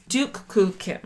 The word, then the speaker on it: Duk Koo Kim: So you said it's pronounced Duke Duk Koo Kim.